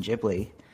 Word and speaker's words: Ghibli: Uh, is it is with a like it's Ghibli, not Ghibli. This is like the big debate. – Uh, pronunciation like according to according to Romanji. – But oh, by the way, it's GIF. The... Just uh, a... God damn you. – Uh, according to the Romanji, uh, on various sources I've I've uh, sourced, it is uh, Studio Ghibli is the proper Japanese enunciation, but we just call it Ghibli. So it is a it is a soft j in there.